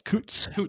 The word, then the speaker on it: Coutts: I mean, in Coutts, if I'm pronouncing, it's Coutts. – Coutts.